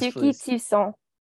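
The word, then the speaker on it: Touki Toussaint: Oh, should I say his name? So everybody can hear how I say his name? – Yes, Tukey Toussaint. – Nice. – It's French. – That's so French.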